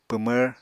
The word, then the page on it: pwmvr: Apatani